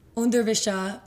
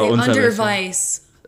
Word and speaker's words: Unterwäsche: Unterwäsche, but I would say Unterweis.